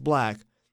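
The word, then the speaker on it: 6LACK: Staying with African R&B acts, though, Oma Lay released Damn, which featured Sixlack, whose name should be pronounced Black, but it's styled Sixlack, so that's what I call him.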